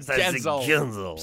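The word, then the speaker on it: Guentzel: Sinon, Jake Gutnetzel. – Jake Genzel. – Genzel. – Genzel. – Ça se dit Genzel. – Ça s'écrit Gutnetzel.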